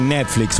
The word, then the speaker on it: Netflix: Y Netflix, sí, Netflix. Lo dije bien, lo había dicho mal, lo dije bien ahora. No me mire así. Me abre los ojos, me dice, ¿qué está diciendo? Netflix,